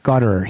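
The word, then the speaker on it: Gottehrer: And the producer of Beauty and the Beat, and I don't know how to pronounce his name, Richard Goddard. – Goddard.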